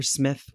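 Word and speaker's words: Psmith: Woodhouse character Smith, where the P is silent?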